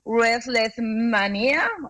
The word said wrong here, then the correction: WrestleMania: o Restless Mania, Russell, Russell, WrestleMania, WrestleMania.